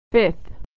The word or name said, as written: fifth